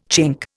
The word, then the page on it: Chink: Indian